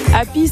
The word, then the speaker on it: Api: Happy, c'est le nom des petites supérettes installées dans les villages où il n'y avait plus du tout de commerce. Ça s'écrit A-P-I.